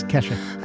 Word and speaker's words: Kesha: I don't even know if I'm saying her name right. – It is Kesha.